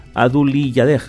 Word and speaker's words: Adulyadej: Este día fue oficialmente reconocido en memoria del rey de Tailandia, Bhumibol Adulyadej. Creo que lo he dicho bien. Adulyadej, sí, efectivamente, es que es un poquito complicado el apellido.